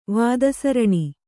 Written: ವಾದಸರಣಿ

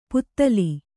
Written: ಪುತಲಿ

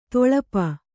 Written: ತಳಪ

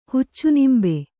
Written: ಹುಚ್ಚುನಿಂಬೆ

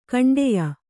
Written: ಖಂಡೆಯ

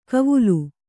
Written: ಕವುಲು

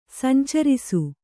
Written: ಸಂಚರಿಸು

